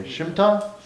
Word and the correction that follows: Shmita: Shmita. – Shmita. Wow, not even close.